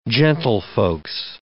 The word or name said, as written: gentlefolks